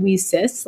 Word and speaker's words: WiCyS: So we often go by our acronym, WiCyS, and we pronounce it WiCyS, like we sisters, because as you know, we're a global cyber sisterhood.